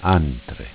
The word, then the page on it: Antre: Dialetto